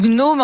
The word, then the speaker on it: gnome: Là, tu veux nous parler du gnome en chef, Michel Marchand. – Alors, j'aime ça que tu le prononces, parce que j'ai de la misère à prononcer ça, le gnome.